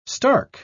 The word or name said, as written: stark